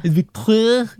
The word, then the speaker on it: Victoire: Victoire.